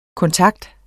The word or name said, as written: kontakt